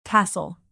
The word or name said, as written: tassel